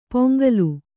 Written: ಪೊಂಗಲು

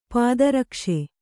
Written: ಪಾದರಕ್ಷೆ

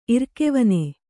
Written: ಇರ್ಕೆವನೆ

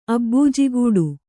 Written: ಅಬ್ಬೂಜಿಗೂಡು

